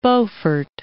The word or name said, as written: Beaufort Sea